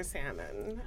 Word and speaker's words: salmon: You pronounce the L in your salmon.